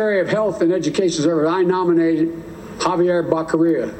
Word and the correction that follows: Becerra: You know, Javier Becerra, excuse me.